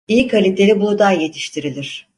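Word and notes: kaliteli: Turkish

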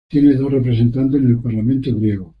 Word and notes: parlamento: spanisch

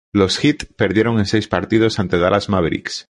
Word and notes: perdieron: ispanskii

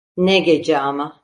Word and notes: ama: Turkish

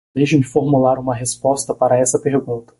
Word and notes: formular: portugues

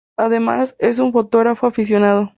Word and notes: aficionado: spaans

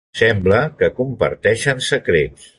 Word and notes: comparteixen: catala

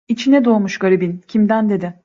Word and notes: dedi: Turkish

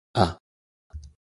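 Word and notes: a: Galician

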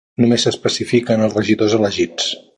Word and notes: regidors: Catalan

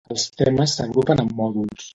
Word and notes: temes: catalan

